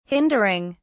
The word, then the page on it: hindering: British English